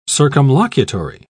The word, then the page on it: circumlocutory: British English